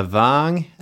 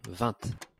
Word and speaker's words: vingt: De stavar ju så här le xx och jag tror att det är då på franska ska uttalas någonting i stil med le vang eller någonting. – Mm-hmm. – V-I-N-G-T. – Vant.